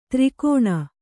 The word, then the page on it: ತ್ರಿಕೋಣ: Kannada